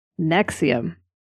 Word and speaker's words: NXIVM: Yeah, so, Albany-based, we are, of course, talking about the sort of godfather of cults disguised as multi-level marketing schemes, Nexium. And for those of you who haven't ever seen that spelled out, that's spelled N-X-I-V-M, because vowels.